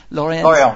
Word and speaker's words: Lorient: Lorient.